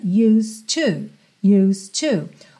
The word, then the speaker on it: used to: O E de used tá pintadinho de cinza, ou seja, não é pronunciado. Você não vai dizer used to. Vai do S direto pro D, que já vira T, para ligar no to.